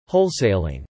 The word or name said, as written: wholesaling